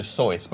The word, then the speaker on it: Seuss: It's actually Dr. Seuss, but we just called it Seuss our whole lives. – Like noise. – Just like that, yeah.